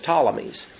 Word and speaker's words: Ptolemies: but if you look at the way it's spelled, it's PT, and really the P is silent, it's just Ptolemies.